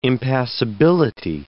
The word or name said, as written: impassability